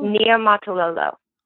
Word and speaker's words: Niumatalolo: Ken Niumatalolo? – Niumatalolo. And I do know I pronounced that correctly.